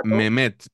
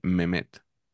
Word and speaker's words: Mehmet: que nos han recriminado en los comentarios que se pronuncia así y además nos dijeron que no solo nosotros, sino en general los periodistas, esto te incluye a ti, nos preocupamos muy poco de cómo se pronuncian los nombres y que se dice Mehmet.